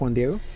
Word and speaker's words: Juan Diego: Yes, Juan Diego.